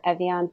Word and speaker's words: Evyan: I'm at Evian period Whitney. My name is spelled E-V-Y-A-N period and then Whitney.